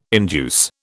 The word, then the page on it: induce: British English